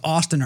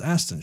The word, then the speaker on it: Astin: So anyway, well, I didn't know if it was Austin or Aston, you know? – I believe it's pronounced Aston, Sean Aston.